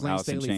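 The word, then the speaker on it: Layne Staley: Lane Staley, I hope I'm not pronouncing wrong. – Oh, yes, Lane Staley from Alice in Chains, yeah.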